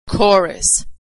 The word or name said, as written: chorus